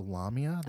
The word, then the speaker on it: Lamia: Am I pronouncing that right? The Lamia? That's how they said it.